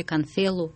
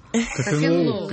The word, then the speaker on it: Конфилу: Вот дальше я могу ударение неправильно поставить. Конфилу или Конфелу? – Конфилу.